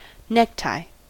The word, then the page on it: necktie: American English